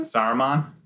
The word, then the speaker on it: Saruman: Saruman.